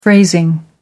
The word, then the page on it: phrasing: American English